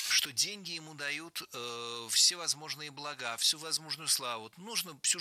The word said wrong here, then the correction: блага: Блага, неверно, правильно, благо. Перед нами, опять же, слово с неподвижным ударением на основе. Не забываем об этом и правильно склоняем. Благо, благом, благами, благах.